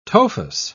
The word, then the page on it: tophus: British English